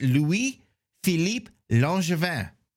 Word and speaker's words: Louis-Philippe Langevin: So I'm going to pronounce our Canadian's name for you. It's Louis-Philippe Langevin, if you're pronouncing it in French.